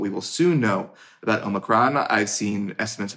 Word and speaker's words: Omicron: Some people are pronouncing it Omicron. On this podcast, I'm saying Omicron.